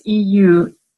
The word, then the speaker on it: Eusebius: That's Eusebius.